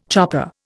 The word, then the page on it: chopper: Indian